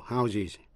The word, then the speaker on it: houses: Vậy số nhiều houses phát âm với âm is. To house phát âm với âm z là cho tạm trú hay là cấp nhà cửa.